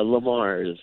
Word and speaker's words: Le Mars: How do I pronounce it? – Uh, Le Mars. – Lamar's.